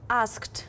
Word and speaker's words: asked: Bei ask haben wir einen harten Mitlaut, K. Ja. Entsprechend wird auch hier hart ausgesprochen. Das ist deins? – Ja, das wäre dann asked. – Asked, genau, richtig. Schönes hartes T hinten dran.